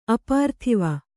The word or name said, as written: ಅಪಾರ್ಥಿವ